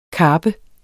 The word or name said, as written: karpe